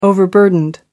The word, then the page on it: overburdened: British English